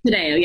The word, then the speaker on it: Taddeo: Taddeo, yes.